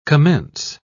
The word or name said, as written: commence